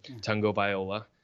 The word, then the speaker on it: Tagovailoa: I can't ever say his name. – Tagovailoa. – There it is.